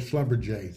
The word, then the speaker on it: Schlumberger: Yeah, Schlumberger. You may have said it right. Here in Houston, we call it Schlumberger.